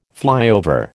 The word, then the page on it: flyover: American English